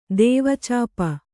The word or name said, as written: ದೇವಚಾಪ